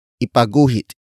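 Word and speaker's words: ipaguhit: Ipagguhit should not be confused with ipagguhit.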